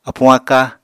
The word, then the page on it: apuñ-aka: Apatani